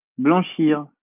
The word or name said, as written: blanchir